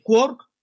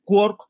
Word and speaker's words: quark: Entonces, según Yelman, Quark como partícula había que pronunciarlo rimando con la ciudad de Cork. – Comprendo. La verdad es que no, nadie entiende el Finnegan's Wake, ¿eh? pero, es verdad que por la rima de las, de las, líneas posteriores tiene que ser Ork.